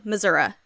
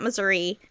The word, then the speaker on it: Missouri: Now I said it right, y'all. Missouri. It's not Missouri. It's Missouri.